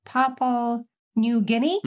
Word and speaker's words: Papua New Guinea: Yeah, I think recently there's been an earthquake in Papua New Guinea.